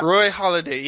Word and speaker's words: Roy Halladay: You brought up Roy Halladay. Did I say that right? My brain is going crazy. Yeah. Roy Halladay, yeah.